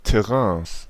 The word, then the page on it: Terrains: /tɛˈʀɛ̃ːs/